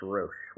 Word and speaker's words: Roesch: Yeah, it's pronounced Roesch.